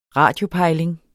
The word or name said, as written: radiopejling